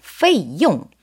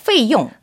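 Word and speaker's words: 페이용 (费用): 네, 비용은 중국어로 그대로예요. 페이용. – 페이용. – 발음 정말 좋게 해주셨거든요. 페이용.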